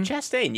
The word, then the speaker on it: Chastain: How do you pronounce that? Is that Chastain? – Chastain. You got it.